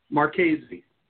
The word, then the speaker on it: Marchesi: Marchesi. – Marchesi, it's with a hard C. Okay. – Like Chianti is a CH, right?